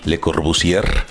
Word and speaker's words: Le Corbusier: Y este pues, es cotidiano para nosotros, pero para las personas que no son arquitectos, a lo mejor Le Corbusier, que se escribe con R al final, Le Corbusier.